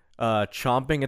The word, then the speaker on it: champing: So yeah, champing at the bit is correct. But chomping at the bit, people have said it wrong for so long that it has become the more common used thing.